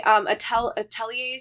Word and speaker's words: Atelier: So the other game I was playing, Atelier, is that how we said we pronounce it? – Oh, yeah.